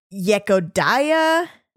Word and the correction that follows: Yevdokia: Yevdokia. – There you Yevdokia Bershanskaya.